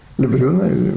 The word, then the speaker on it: Лебрюн: Начинается это все дело с 1718 года, с путешествия, с описания путешествия Лебрюина. Лебрюна, по-разному она произносится в России. Наверное, Лебрюн все-таки, да.